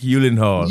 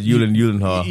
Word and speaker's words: Gyllenhaal: Jake Gyllenhaal. – Gyllenhaal. Gyllenhaal. – I added some other Yulins in there. I said Yulin Gyllenhaal. – You did, yeah. But it's Gyllenhaal.